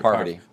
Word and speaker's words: Parvati: I think one quality that RC has, a Parvati. I always cannot pronounce her name right. – Parvati.